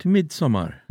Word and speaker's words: Midsommar: I think it's the spelling implies you should pronounce it like a Swede. – Midsommar.